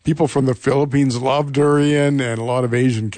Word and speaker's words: durian: And the answer is durian, or dorian, I've heard it pronounced.